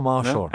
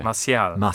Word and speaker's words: Martial: No, it's not Marshall. – No? Anthony Marshall? – No. – Okay. – Martial.